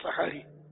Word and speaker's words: سحری: تلفظ تو یہی ہے سحری, سحری بولتے ہیں, سحری بولتے ہیں عام طور پر, سحری میمنی میں سحری.